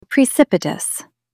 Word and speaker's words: precipitous: Put the stress on the second syllable. Pre-sip-i-tus.